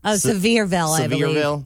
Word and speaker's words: Sevierville: Sevierville? – Sevierville.